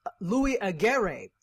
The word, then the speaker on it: Louis Aguirre: Now, I didn't really watch it all the time, but I was interested a little bit because one of the co-hosts, uh, Louis Aguirre, Louis Aguirre, is how you say his name.